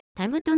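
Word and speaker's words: 椨: また、タブという漢字は、木片に文書や財宝を入れる蔵、または物事がたくさん集まるところという意味を持つ、ふと書いてタブとなることから、大変縁起のいい意味を持つ木です。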